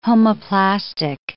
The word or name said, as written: homoplastic